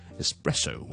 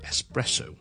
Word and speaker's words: espresso: 咁其實就唔需要，E-S 咧，我就咁 S-S-S 或者 is 就得。咁我哋就唔需要咧，好似 express E-X 嗰個 X 咧，就讀成 X 加 K 喺度嘅。 OK，只需要 espresso 或者 espresso。